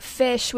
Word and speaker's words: Phish: It's spelled P-H-I-S-H for anybody that doesn't know.